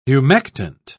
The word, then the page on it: humectant: British English